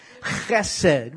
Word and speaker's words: chesed: Chesed. Want to try it? Chesed. Yeah, clears all the... Chesed. C-H-E-S-E-D.